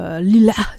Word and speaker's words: Lila H: Album qui s'appelle Lila, si on le prononce comme il se doit, avec l'accent arabe, ou Lila H, si vous voulez le prononcer à la française.